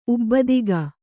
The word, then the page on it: ಉಬ್ಬದಿಗ: kannada